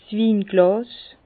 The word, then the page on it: 's Winglàs: Bas-Rhin; Herrlisheim